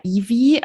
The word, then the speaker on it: Evie: Und ich habe immer gedacht, die heißt, das ist eher Evie. Aber irgendwo im Buch wird dann erklärt, dass es ausgesprochen wird wie Chevy, also das, die Car-Brand.